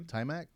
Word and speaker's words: Taimak: Taimak.